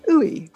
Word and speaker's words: Ooi: You can find it by searching for Creative Conversations and my surname, Ooi, which is spelled OOI.